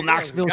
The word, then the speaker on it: Knoxville: What do you expect from a town with a silent K at the start? We're going to start a whole Knoxville Chicago thing.